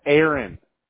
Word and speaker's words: Arron: I have A-R-R-O-N. Aaron? – How would you pronounce that? Aaron.